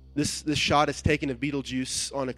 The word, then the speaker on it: Betelgeuse: The star is called Betelgeuse, or Betelgeuse. You can pick your pronunciation. I'm going with Betelgeuse because that's awesome.